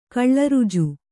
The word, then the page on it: ಕಳ್ಳರುಜು: kannada